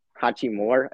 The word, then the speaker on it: Hachimura: Hachimura? – Hachimura.